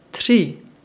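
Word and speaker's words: tři: Der Buchstabe R mit Häkchen ist dafür einmalig und für die deutschsprachige Zunge schwierig. Ausgesprochen wird es als R, zum Beispiel Reka, auf Deutsch Fluss, oder Tri, drei auf Deutsch.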